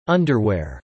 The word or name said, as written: underwear